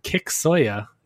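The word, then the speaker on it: Kiksuya: Kick Soya is how I would pronounce it. – Yep, sounds right to me.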